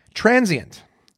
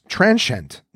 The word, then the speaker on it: transient: It has 2 syllables, not 3. So it's transient, not transient. It actually has that. So that was 0 for 2 for me, because again, I say transient. But it's not. It's transient. T-R-A-N hyphen S-H-E-N-T. So transient is how it's supposed to be said.